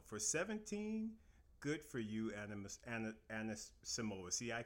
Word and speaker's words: Anisimova: Anisimova. – Anisimova, yes.